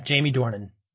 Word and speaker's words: Dornan: Another issue I had was that Anthony Mackie and Dornan's, is that the way you pronounce it? – Yeah, Jamie Dornan.